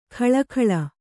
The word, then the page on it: ಖಳಖಳ: khaḷa khaḷa